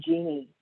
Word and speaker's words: Jini: It's spelled J-I-N-I, but pronounced like the woman's name, Genie.